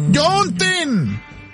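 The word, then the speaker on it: Juneteenth: En todo el país hoy se conmemora el Juneteen. ¿O cómo se dice? – Juneteen.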